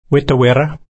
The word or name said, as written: wita-wirra